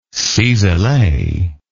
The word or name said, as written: cisele